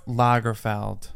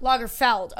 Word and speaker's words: Lagerfeld: How do you say his last name? Lagerfeld? – Lagerfeld. – Lagerfeld.